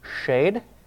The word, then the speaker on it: shed: Now in the Hebrew, that's the term shed. That's how you pronounce it.